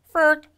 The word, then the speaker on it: FERC: A state agency in Maryland has filed a complaint with the Federal Energy Regulatory Commission, known as FERC, or if you pronounce it, FERC.